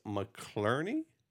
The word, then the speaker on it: McClarney: And this week, we are ending the show out with a song from Dara McLean featuring Chris McClarney. – Yeah, you know, I'm McClarney. Saying that right. – Yeah. – Clarney? – Yeah, two Irish, good Irish names there.